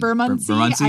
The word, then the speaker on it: Bermondsey: Yeah, there's the old Barmancy Tannery, which was abandoned. – Bermondsey? I don't know how you say it anymore. – Yes. You're probably more accurate than I am.